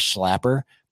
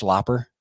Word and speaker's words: Schlapper: he always calls him the Bass Schlapper, but it's actually pronounced Schlopper. Like, you don't say his name Schlapper, it's Schlopper.